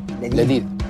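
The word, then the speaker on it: Ladid (لذيذ): Ladid.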